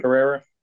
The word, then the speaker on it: Pereira: Pereira.